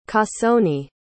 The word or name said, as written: cassone